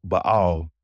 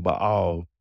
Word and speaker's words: Baal: Now, you may hear me saying Baal. That's the correct Hebrew pronunciation of it. But you may hear most people say Baal, and we're not nitpicky people. So either of those are fine if you want to be technical and say it the right way, or if you just want to say Baal.